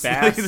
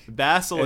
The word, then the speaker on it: basilisk: There's no, yeah, there's no K after the S. Just look how it's spelled. Just, basilisk. Like, basilisk. – Basilisk.